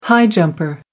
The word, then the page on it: high jumper: American English